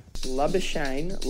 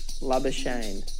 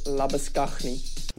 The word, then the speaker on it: Labuschagne: Labuschagne like champagne. So like champagne, Labuschagne. That's the perfect pronunciation.